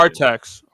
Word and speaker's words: Artax: Artax, Artax, Artax, Artax.